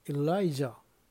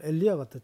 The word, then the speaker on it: 엘리야: 네, 21절 엘리야, 엘라이자, 영어 발음이죠. 히브리 말을 읽는 유대인 발음에 우리 한국말 엘리야가 더 정확합니다.